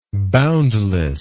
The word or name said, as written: boundlessness